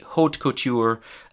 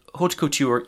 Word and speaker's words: haute couture: That's the French pronunciation. In English, we'd probably just say haute couture. Um, and haute couture is the practice of creating extremely high quality, high fashion clothing.